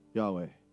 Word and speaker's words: Yahweh: When you correctly pronounce these consonants together, if you do it correctly, your lips and your tongue, your tongue would not be used and your lips will not close. Yahweh.